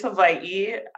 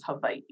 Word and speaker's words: Hawaii: Technically, it's Hawaii. I interchanged with Hawaii just because I did live on the continent for a bit. And when you say Hawaii, the conversation kind of stops there. So admittedly, I have like assimilated with the correct, incorrect pronunciation of it, but it is Hawaii.